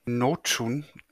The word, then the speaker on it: Nocun: Ja, von äh, Katharina Notschun wird es, glaube ich, ausgesprochen.